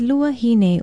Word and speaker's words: luahine: We told you about elemakule, which means old man, and today's Hawaiian word of the day is luahine, or old woman. It is spelled L-U-A-H-I-N-E and can also mean an old lady.